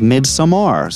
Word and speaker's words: Midsommar: You know, for the longest time, I was calling this Midsommar, but then I recently watched the special features with Ari Aster talking, and his pronunciation was Midsommar, so I want to be accurate here. You know, it sounds kind of silly after saying Midsommar for so long. Makes sense with that A at the end, right?